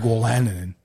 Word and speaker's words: Wolanin: I think it's Wolanin. – It's Wolanin. I'm not sure. I knew his father is Wolanin. – Craig? – Yeah. – Okay, so Wolanin.